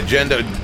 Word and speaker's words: Gendo: Gendo or Gendo? – Yeah, Gendo. G-E-N-D-O. Second word is I-K-A-R-I.